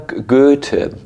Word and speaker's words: Goethe: I would like to speak here in the word of another German author, Johann Wolfgang Goethe. That's how we say it in German language. How would we pronounce Goethe in the English language?